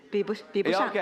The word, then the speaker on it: 比不上: But 美国的黄瓜 hey, 不能比 Or is it the other way? Did I say that right? – 比不上 Okay, cannot compare with the American cucumbers cannot compare with 天津黄瓜 Oh, did I just say that?